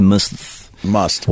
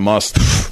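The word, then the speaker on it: musth: They blame the elephant's aggressive behavior on the fact that he was in a heightened state of sexual tension known as must. – What? – Yeah. Must. You don't pronounce the H? – I don't think so.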